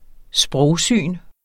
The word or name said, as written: sprogsyn